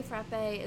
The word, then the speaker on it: frappe: And then it also kind of says that in the Boston area, a frappe, pronounced frap and spelled without the accent, is a thick milkshake with ice cream derived from the French word frappe.